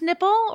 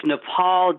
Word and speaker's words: Nepal: PJ Byrne, Professor Warren, is it pronounced Nipple or is it Nepal? – It's Nepal, damn it. It is Nepal.